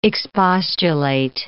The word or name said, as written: expostulate